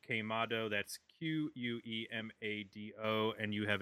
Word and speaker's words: Quemado: We have a new 7-inch out called Quemado, pronounced Quemado. And it's three songs on Anyway Records.